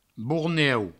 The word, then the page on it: borneu: bourneu